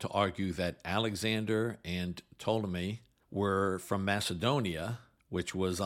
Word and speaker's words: Ptolemy: One of his generals was Ptolemy. And by the way, that's spelled with a silent P, P-T-O-L-E-M-Y.